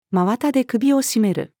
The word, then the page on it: 真綿で首を絞める: female